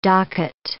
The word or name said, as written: docket